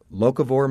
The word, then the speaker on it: locavore: Stacy, am I pronouncing it correctly? Locavore movement? – Yes, indeed you are.